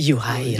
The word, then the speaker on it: Juraj: Personnellement, moi, j'ai de la difficulté avec Jurav Slavkovski. Oui, Juraj.